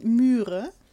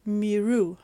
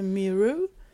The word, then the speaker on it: Myre: Also man könnte denken, dieser Comic würde heißen Myre, weil er M-Y-R-E geschrieben wird, Buch 1. Aber wir haben gelernt, also es gibt nämlich ein Interview mit der Autorin Claudia Schmidt, und das ist ein Mix aus englischer und deutscher Aussprache sein soll. Und wenn ich es jetzt richtig mache, müsste es Miru heißen.